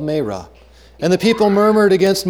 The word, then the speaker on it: Marah: And when they came to Marah, now you might have to excuse me, for all of my life I have heard that pronounced Mara, and I've pronounced it that way. And according to my phonetic Bible, the pronunciation is Marah. It's a long A.